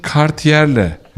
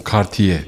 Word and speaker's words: Cartier: Peki Cartier'le. Ama sonundaki R okum Cartier diye okunuyor. – Cartier. – Evet, orada R yok.